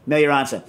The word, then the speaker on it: Melioranza: So Tom, and this is how you pronounce his name, Melioranza, See, it says he. I like it when people give me the pronunciation, but he said you have to say it fast.